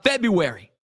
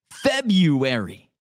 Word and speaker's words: February: Do you say the R? In the United States, the most common pronunciation is February. February. While dictionaries consider the pronunciation correct, many consider the less common February, the more traditional standard, meaning you pronounce the R first.